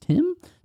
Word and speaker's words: Tynn: And then the past member was, I believe his name is pronounced Tim, yeah. – T-Y-N-N, Rangers.